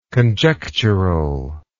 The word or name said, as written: conjecturally